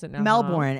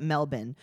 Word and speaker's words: Melbourne: It's like she's like Melbourne. And he's like, actually, we call it Melbourne. And she's like, oh, so you don't pronounce the R.